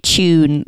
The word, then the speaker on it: Chewn: You should check out this zine called Tune, that's spelled C H E W N, that is like about bands and food, and it's sick.